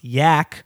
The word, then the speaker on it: Jack: Jack Michael Doyle is the American name of John Jack, pronounced Yak.